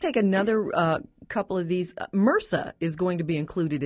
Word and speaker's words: MRSA: It's a all capital abbreviation, MRSA, pronounced MRSA, you're right.